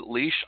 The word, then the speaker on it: Laois: It's Andrea Carter, who grew up in Laois, Ireland, and it's not spelled the way it sounds,